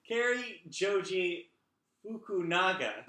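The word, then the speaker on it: Cary Joji Fukunaga: Kerry Joji Fukunaga.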